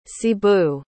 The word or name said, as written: cebu